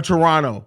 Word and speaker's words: Toronto: Or Toronto. I'm sorry. The Canadians always correct me, especially apparently you're not supposed to pronounce the second T.